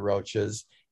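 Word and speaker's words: Roches: And for those of you who don't know the Roaches, it's not Roach as in the insect, it's their last name, and it's spelled R-O-C-H-E.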